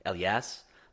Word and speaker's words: Eliyas: I think the like proper way to say it is Elias, like almost like. E-L-I-Y-A-S. Like, Elias.